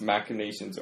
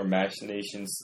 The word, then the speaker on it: machinations: Sometimes I've heard it pronounced machinations or machinations to seize power.